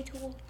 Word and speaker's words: tulle: What is a tool? – You are a tool. – Yeah, she's not calling you a tool. – Tool. It is spelled T-U-L-L-E.